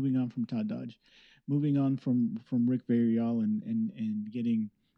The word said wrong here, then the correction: Villarreal: He pronounces it Villarreal.